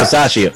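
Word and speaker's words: Bisaccia: How do you pronounce his last name? – Yeah. Passaccia.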